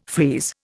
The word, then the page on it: freeze: Indian